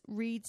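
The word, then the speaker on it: Wrede: Reed, and it's Reed spelt with a W, so it's W-R-E-D-E.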